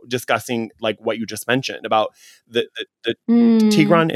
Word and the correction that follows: Tigray: Is that right? Is that how you say it? – Tigray region.